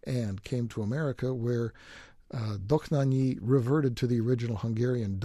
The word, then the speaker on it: Dohnányi: They do pronounce it different. And it's kind of an interesting story. The original Hungarian pronunciation apparently is Dohnányi, but the family moved to Germany and Germanized it to von Dohnányi.